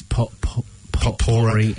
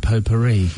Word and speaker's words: potpourri: I mean, but talk me through, do you think it's Rammstein or Rammstein? – I think it's the same as potpourri and potpourri. – I said potpourri. Is that not factually... – I think you could say both pronunciations, yes, but we know who you're talking about.